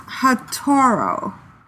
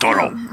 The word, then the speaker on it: Hatoro: It is pronounced Hatoro, and it's a Japanese name that means firefly. But please don't Hatoro.